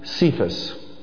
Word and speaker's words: Cephas: But in Aramaic, his name would be pronounced Cephas. That's spelled C-E-P-H-A-S. Cephas.